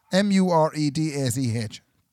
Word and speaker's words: Muiredach: We ended up enlisting the help of me neighbour Muirdag and it was a very good life lesson. – Who? – Muirdag. – Who? – Have you never heard the name Muirdag? – Muirdag. Spell it. – Muiredach.